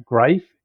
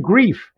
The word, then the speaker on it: Greif: And just so you feel better, Greif, I always tell people Greif rhymes with life. And I was being introduced and there was a couple of us and I said, Greif rhymes with life. And he gets up there and he announces, Martin Greif. And the guy next to me goes, must rhyme with leaf.